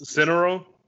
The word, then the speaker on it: Cineron: I should have asked you like how exactly to pronounce your last name before we did this because I'm probably gonna butcher butcher it. But we got Timmy Cineron. Uh, uh, that's right. That's right. That's Cineron.